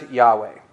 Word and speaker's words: Yahweh: We pronounce this sometimes Yahweh.